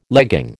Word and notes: legging: American English

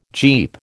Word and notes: Jeep: American English

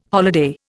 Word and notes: holiday: Indian

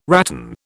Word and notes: rattan: British English

